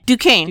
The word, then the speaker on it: Duchesne: Uh, I'm gonna say Duchesne. Kelsey Duchesne. How you spell it? D U C H E S N E. Duquesne. Duquesne. Okay. Kelsey Duquesne.